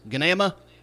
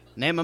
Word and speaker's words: Gnama: I actually did catch up with them on Facebook this week and say, how exactly do I pronounce that? Because is it Gnama? Nama Mama? Like, we had a whole debate about that on the last show, and I've already butchered it enough, so what is it? And he did confirm it is NAMA. And the G is silent.